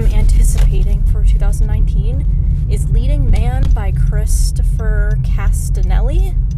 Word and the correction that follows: Castellani: Castanelli? Yeah. Castellani. I definitely said that wrong.